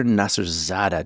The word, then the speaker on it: Nasserzadeh: You are Dr. Nasserzadeh.